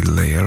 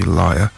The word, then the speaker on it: Lia: First of which is from, now I'm going to pronounce this Leah, L-I-A. It's got to be Leah, hasn't it? It won't be Liar. Nobody wants to be called Liar.